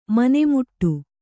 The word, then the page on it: ಮನೆಮುಟ್ಟು: mane muṭṭu; kannada